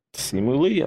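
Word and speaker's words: Simu Liu: L-I-U is pronounced L-E-E. Lee. – Simu Lee, I'm telling you.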